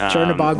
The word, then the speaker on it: Chernabog: And the one that I thought was most interesting, I had not thought of, that came up was the, I don't know if I'm saying this right, the Chernabog. – Yes, Chernabog was my number one.